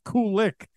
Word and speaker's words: Kulick: And then I remember you said you like to go with Kulick because it's like cool licks on a guitar. – Yeah, Karabi always used to call me on lead guitar, Bruce Kulick, you know.